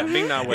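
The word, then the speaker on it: Ming-Na Wen: Yeah, Ming Na Win.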